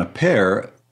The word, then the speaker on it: Appert: And of all people, a confectioner and chef in Paris named Nicolas Appert, A-P-P-E-R-T. You're better at the French pronunciation. – That would be correct. – Would yeah, Appert.